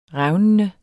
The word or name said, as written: revnende